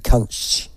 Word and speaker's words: Kuntzsch: Go to Jodie Kunch. That's how it's pronounced. KUNTZSCH.